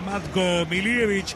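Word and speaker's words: Matko Miljevic: Muy bien, se saluda el árbitro del partido con Nachito Russo, también va Matko Milievich, así se pronuncia, aunque usted escucha a un montón de relatores y comentaristas que no lo hacen de ese modo.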